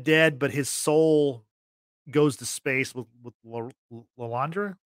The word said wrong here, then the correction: Leandra: Leandra, how do you say it? – Yeah. – Leandra. Like, Leandra. Right? Yeah, okay.